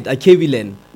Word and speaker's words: Akebulan: Am I saying it right? Akebulan? – Yeah, you are.